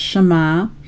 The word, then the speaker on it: Shema: It is Strong's number 8085, and again it's pronounced Shema.